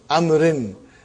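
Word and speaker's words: amrin (عمرو): Haza kitabu amrin. Waw is still written. Are you with me? It's not pronounced. It's just to, why it is written? To differentiate between Umar and Amr.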